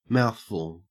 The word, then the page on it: mouthful: Australian English